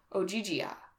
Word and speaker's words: Ogygia: Ogygia. – Ogygia.